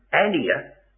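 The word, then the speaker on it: anēr: About 50 occurrences of this word in the New Testament, I'll tell you what it is, A-N-E-R, a long E, an ear, 50 occurrences, and every single one of them is a man as distinct from a woman.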